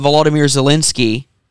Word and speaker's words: Volodymyr Zelensky: Now, I will say at least President Volodymyr Zelensky. – You said his name correctly.